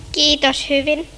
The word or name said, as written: hyvin